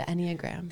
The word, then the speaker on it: Enneagram: Which, did I pronounce that right? – The Enneagram. – The Enneagram. – Which is Greek.